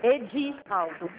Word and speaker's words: Edivaldo: Emivaldo? – Edivaldo. – O D não é mudo, não, né? – É D-I. Edivaldo Araújo Ponte.